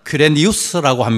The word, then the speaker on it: 퀴레니우스: 이 구레노는 확실한 발음을 말하면 퀴레니우스라고 합니다. 헬라말로서는.